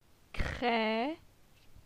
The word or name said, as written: Cre